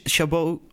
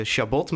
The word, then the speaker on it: Chabot: En ik dacht, nou, Duits, uh, Chabot. Maar kennelijk moet komt hij uit de Elzas of zo, eh, moet dat op een Franse manier uitgesproken worden.